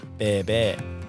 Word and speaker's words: bé bé: Our last but not least no-name venue in Vung Tau is building a fine reputation for their specialty, bún bé bé, pronounced bé bé.